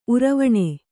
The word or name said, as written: ಉರುವಣಿ